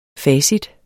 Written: facit

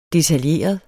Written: detaljeret

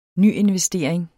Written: nyinvestering

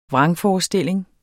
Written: VRANGFORESTILLINGERNE

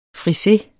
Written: frise

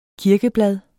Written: kirkeblad

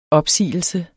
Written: opsigelse

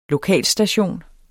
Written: lokalstation

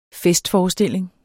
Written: festforestilling

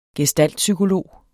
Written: gestaltpsykolog

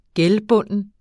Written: gældbunden

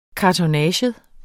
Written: kartonnage

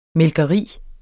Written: mælkeri

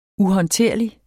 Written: uhåndterlig

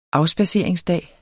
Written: afspadseringsdag

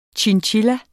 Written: chinchilla

